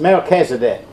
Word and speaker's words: Melchizedek: Everybody knows about Melchizedek, correct? Do you have trouble pronouncing that, anybody? It's Melchizedek.